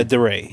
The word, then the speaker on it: DeRay: Yeah, DeRay.